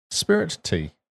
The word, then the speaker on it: Spiritea: So, Spiritea, or Spirit T. Not sure how you pronounce this game. – Spirit tea. – Well, it's spirit and tea, or is it a bit smarter than that? – It's literally spirit tea.